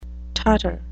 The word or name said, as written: tartar